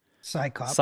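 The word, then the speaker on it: CSICOP: how do you say this? – Psychops. Psychops. – That's right. C S I C O P S, the Committee for the Scientific Investigation of Claims of the Paranormal.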